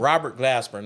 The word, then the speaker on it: Glasper: I was looking for everybody that was in it, but who won was a guy by the name of, and correct me if I'm wrong, Robert Glasper. – Yes. – That's right. – I think you said it correctly. – Robert Glasper.